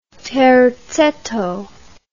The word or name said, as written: terzetto